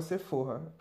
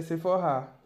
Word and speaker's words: Sephora: Até ontem eu achava que se pronunciava Sephora, para vocês verem como eu entendo de maquiagem. Na minha pesquisa que eu vi que a pronúncia é Sephora.